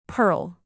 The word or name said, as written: perl